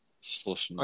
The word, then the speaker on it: Schlesinger: She's Schlesinger? Schlesinger? – Schlesinger. – I can't pronounce the name. Schlesinger. That's it.